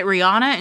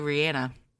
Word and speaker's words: Rihanna: And she pronounces it Rihanna. – Rihanna? – And in this interview, they asked her, is it Rihanna? And she said, no, it's actually Rihanna.